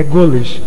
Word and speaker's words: goles: O primeiro deles, gois, G-O-I-S, com a pronúncia do O fechado, como o da palavra bois. O segundo plural é goles, G-O-L-E-S, também com O fechado.